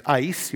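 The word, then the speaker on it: ice (εἰς): That's a word, a small little Greek word that is pronounced ice. You want to say ice?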